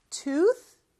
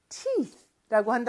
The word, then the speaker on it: tooth: tooth하고 teeth라는 거를 잘 기억해 주셔야 되는데 그 마지막 th 발음이 조금 어려울 수 있을 것 같아요. 이렇게 바람 소리거든요.